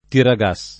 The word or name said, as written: tiragas